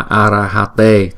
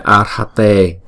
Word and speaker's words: arhate: Chữ arhate được phát âm là arahate thay vì là arhate, tức là ba âm.